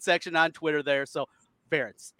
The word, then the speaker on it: Ferentz: Nobody knew how to say his name, but yes, Ferentz rhymes with parents.